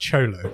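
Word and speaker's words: Xolo: You know, it's pronounced Cholo. Cholo Maraduena.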